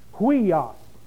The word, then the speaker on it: Huios: Weos. Actually, it's pronounced hui with an H-W-E-E. Huios.